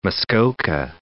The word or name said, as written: Muskoka, Lake